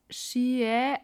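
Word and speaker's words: Sidhe: Man spricht jetzt übrigens Shie aus. Also dieses D wird nicht ausgesprochen.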